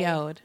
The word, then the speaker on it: Yod: Now, I want to let you guys know that according to a few different articles I read about Father Yod, it is not pronounced Yod. It is pronounced Yod as in load.